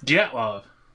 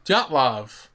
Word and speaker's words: Dyatlov: Dyatlov. Dyatlov.